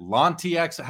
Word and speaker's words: L'Anti Expo: Is it L'Anti Expo? How do you how do you say the show? – Okay, well, French is l'anti expo, so it is anti expo, meaning it's a uh, it's a, a demeanor on on an expo.